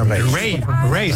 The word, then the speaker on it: Raye: Ray. Se escribe Raye.